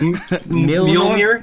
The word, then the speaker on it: Mjolnir: Mjolnir.